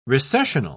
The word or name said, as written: recessional